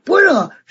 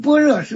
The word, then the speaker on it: 般若: 实际上啊，我们般若是我们现在这么念的。其实你知道，要是真正啊梵文的读音怎么读，跟我们这个读音差的太多了。那什么巴尼亚就这么读的，巴尼亚我们是跟般若差太多了吧？